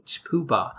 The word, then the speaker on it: poohbah: It's spelled P-O-O-H-B-A-H, poobah.